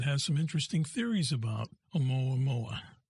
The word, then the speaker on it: Oumuamua: Now, I'm not sure of the pronunciation, so please, Oumuamua? – Yeah, Oumuamua.